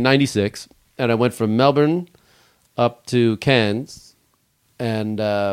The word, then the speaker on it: Melbourne: You have to learn to like get rid of the R sound. – You can spell Melbourne. You can spell it M-E-L-B-A-N. Melbourne.